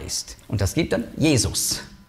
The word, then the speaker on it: Jesus: Das ist die griechische Aussprache von Hebräisch Jeshua. Und bedeutet genau das Gleiche. Und es gibt Leute, die denken, sie seien noch ein bisschen besser und biblischer, wenn sie sagen Jeshua. Kein bisschen. Ist einfach die andere Aussprache.